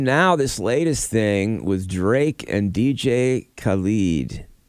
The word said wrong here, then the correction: Khaled: Did I say that right? I think so. – Probably Khaled. – It might be Khaled. DJ uh, well, Khaled.